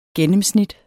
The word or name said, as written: gennemsnit